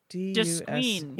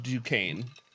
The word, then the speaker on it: Duquesne: Duquesne. – Yeah. Duquesne. – There we go. There we go. But it's pronounced Duquesne.